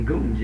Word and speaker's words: gums: Well, my opinion, the right pronunciation is gums, isn't it? – Well, it depends on where you are again. – Uh-huh. – The middle of the form. Different yeah. – than the north.